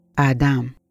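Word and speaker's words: آدام (אדם): آدم كلمة عبرية ومذكور اسمه في التوراة واسم آدم يعني الطبيعة البشرية وقد تعني كلمة رجل أو إنسان وأحيانا تعني اللون الأحمر وتنطق كلمة آدم باللغة العبرية آدام فدم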